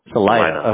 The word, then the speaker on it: Salida: So it's not just, so is it Salida? Am I saying that right? – Salida. – Salida.